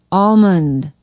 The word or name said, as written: almond